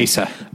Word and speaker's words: Asa: Ethan Hawke and Asia Butterfield. I don't know if that's how you say his name. – Asa. – I'm going to stick with Asia. – Okay. – Even though it's spelled A-S-A.